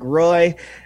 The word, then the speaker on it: Roy: This one is just for Drew, too, because I'm going to quantum leap into Patrick Roy. When they I appreciate you said his name right.